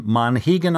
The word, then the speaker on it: Monhegan: Am I pronouncing this next one right off the coast of Maine, Monhegan Island? – Yes, that is correct. And lobster traps line unpaved roads. I love that description. It's an art community, and they have a Monhegan Museum of Art and History.